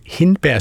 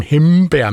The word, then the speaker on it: hindbær: Hun synes også, at det er noget rod, når folk kommer til at sjuske og udtale hindbær som hembær med M, som i Mikkel, i stedet for N, som i Nikolaj.